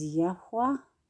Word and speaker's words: Yahweh: We can say Yahweh, but the correct pronunciation is Yahweh. So I'm going to say Yahweh. It's more of a breath.